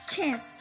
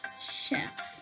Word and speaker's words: chef: Algunas veces pues hemos pronunciado mal porque hemos pronunciado chef y no es chef, sino es chef.